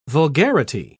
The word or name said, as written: vulgarity